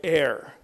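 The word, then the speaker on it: e'er: Some poets will say, well, I can't write over, so I'm going to write or, right? O apostrophe E-R. Or ever, it's two syllables. I only want one syllable here, and we're going to write air.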